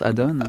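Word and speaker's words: Adon: C'est à ce moment-là aussi qu'il rencontre donc celle qui deviendra sa femme, Adonne Lacroix, c'est comme ça qu'on prononce Adonne.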